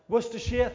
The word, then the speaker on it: Worcestershire: Worcestershire, thank you. One of those words that isn't pronounced the way it's spelt.